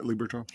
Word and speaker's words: Liberatore: Liberatore, am I pronouncing that right? – Yeah, Matt Liberatore.